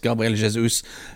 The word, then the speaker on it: Gabriel Jesus: Ellers, en annen spiss, Gabriel Jesus. Eller er det Gabriel Jesus? Han er brasilianer, så er portugisisk, så det gjenst skal det fort uttales, Gabriel Jesus.